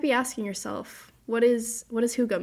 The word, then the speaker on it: Hygge: Never mind. It's pronounced Hygge.